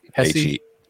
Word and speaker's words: Hesse: How do we say this? – Hesse, H E S S E. I know we're in I, Australia. I think, I think it's Hess. Uh, Bavaria, uh, many more uh, listeners than Vermont.